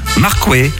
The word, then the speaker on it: Marquois: Autre lieu dit à parcourir, Marquois, prononcé Marquet, en parler local.